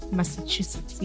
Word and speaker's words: Massachusetts: Massachusetts, yes, said it right.